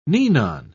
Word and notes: ninon: British English

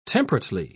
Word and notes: temperately: British English

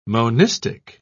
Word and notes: monistic: British English

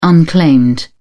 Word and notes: unclaimed: British English

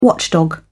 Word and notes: watchdog: British English

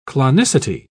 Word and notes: clonicity: British English

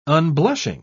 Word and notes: unblushing: British English

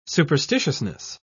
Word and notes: superstitiousness: British English

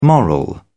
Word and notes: moral: British English

